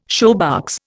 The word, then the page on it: shoebox: Indian